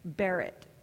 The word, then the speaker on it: berit: And it sounds very similar to the word for covenant, berit, B-E-R-I-T.